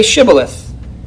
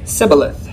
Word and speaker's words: Shibboleth: Then they'd say unto him, "Say Shibboleth," and he would say Sibboleth, for he could not frame to pronounce it right.